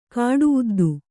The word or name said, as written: ಕಾಡುಉದ್ದು